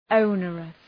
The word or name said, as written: onerous